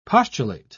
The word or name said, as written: postulate